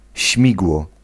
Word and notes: propeller: American English; /pɹəˈpɛl.ə(ɹ)/

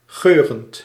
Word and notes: odorant: France; [ɔ.dɔ.ʁɑ̃]

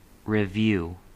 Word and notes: revue: /rə.vy/; /ʁə.vy/

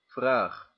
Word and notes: vraag: /vraːx/

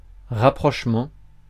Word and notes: rapprochement: /ʁa.pʁɔʃ.mɑ̃/

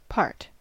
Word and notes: part: American English; /pɑɹt/

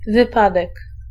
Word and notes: wypadek: /vɨˈpadɛk/

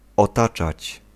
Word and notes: entourer: [ɑ̃.tu.ʁe]

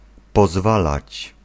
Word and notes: permettre: France; /pɛʁ.mɛtʁ/

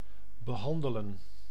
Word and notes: manier: France; /ma.nje/

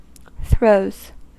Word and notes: throws: American English

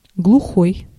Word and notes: deaf: /ˈdɛf/

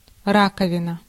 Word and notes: раковина: /ˈra.kə.vʲɪ.nə/

